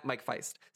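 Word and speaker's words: Faist: And he said, Mike faced Wonka from West Side Story and the upcoming Challengers. And I think it's, is it Faced? – Feist. – I don't know how you pronounce it. Mike Feist.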